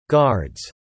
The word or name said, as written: guards